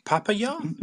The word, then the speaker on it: Papayaw: Papaya, is that how we pronounce that? – Yeah, Papaya, or you can call me George.